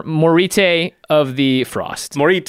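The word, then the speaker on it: Moritte: Like, why is the last T and E there if they make no sound? – Morit. Oh, maybe it's Morit.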